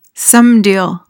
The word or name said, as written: somedeal